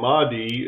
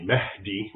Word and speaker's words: Mahdi: So the Mahdi, as an English speaker would pronounce it, it's really Mahdi, which basically means guided.